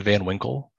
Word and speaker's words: Van Winkle: Yes, my name is Ian Van Winkle. That's spelled I-A-N V-A-N space W-I-N-K-L-E.